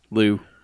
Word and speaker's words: Lew: I like that it's spelled L-E-W. – Also. Oh, of course, yeah. – Lou.